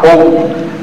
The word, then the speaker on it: Om: Ah, Om. Se pronuncia Om y la resonancia es Om, decía el maestro.